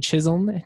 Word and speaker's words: Chisholm: I'm with Henry Chisholm.